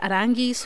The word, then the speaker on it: Aránguiz: Amiri can work as a holding midfielder next to someone like Julian Baumgartlinger or Charles, apparently pronounced Charles Aránguiz, who was absent today from the game.